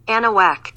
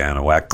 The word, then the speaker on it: Anahuac: So their Texas Gator Fest is happening September 16th, 17th, and 18th in Anahuac, Texas. I think that's how you pronounce it. – Anahuac. – Yeah, okay, Anahuac. Close enough. And at least that's what the computer says. I'm sure one of the Anahuacans will say, that ain't how you say it, boy.